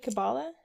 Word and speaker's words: Kabbalah: Kabbalah? – Yeah.